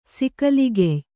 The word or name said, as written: ಸಿಕಲಿಗೆಯ್